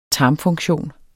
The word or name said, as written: tarmfunktion